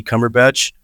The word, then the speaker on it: Cumberbatch: It's Eddie Cumberbatch, spelled like Benedict Cumberbatch spells his name.